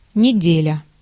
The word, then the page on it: неделя: /nʲɪ.ˈdʲe.lʲə/